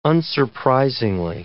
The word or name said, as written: unsurprisingly